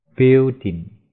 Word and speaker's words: building: 词尾，passport 护照，safe 安全的、平安的，pet 宠物，speak 说讲，注意 sp 要发 sp，speak speak，building 建筑物，注意这个 i 没有发音。顶就是我们听到顶，一般都是 d i n g building。